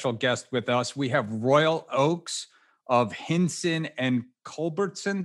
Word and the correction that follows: Hinshaw and Culbertson: Did I say that correctly, Royal? – You were close. It's Hinshaw and Culbertson.